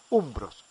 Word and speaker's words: umbros: Más adelante los etruscos tomaron muchas posiciones suyas y, y se debilitó la cultura de los umbrios, umbros, que así se pronuncia, la umbría en la región.